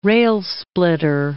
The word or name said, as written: rail-splitter